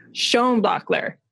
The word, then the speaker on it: Schoenbaechler: Schoenbaechler.